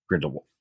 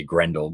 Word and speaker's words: Grindelwald: Grindelwald. – I always say Grendel, but I always mistaken it with Beowulf.